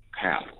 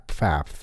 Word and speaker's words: Pfaff: Is it Pfaff? I don't know how to pronounce it, but he's a... – It's Pfaff. Right. Pfaff.